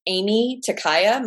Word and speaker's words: Aimee Takaya: So I can be followed on Instagram at Aimee Takaya.